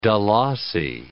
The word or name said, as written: dalasi